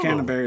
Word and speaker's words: Canberra: Canberra, is that how you say it? – Yeah. – Is it? Oh. – Canberra is the capital.